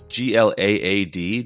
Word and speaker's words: GLAAD: It's, you said the GLAAD Media Award, but it's GLAAD. Do they say GLAAD? Is that how they pronounce it? – Yeah, that's how they say it.